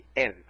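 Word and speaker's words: Él (אל): Fíjense, cuando dije Él, no estoy hablando del artículo español, Él, sino de una palabra hebrea que se dice así, Él, Aleph Lamed, Él.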